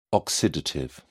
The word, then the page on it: oxidative: British English